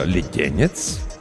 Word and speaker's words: Леденец: «Пусть госпожа Леденец...» – на сей раз доктор сделал правильное ударение.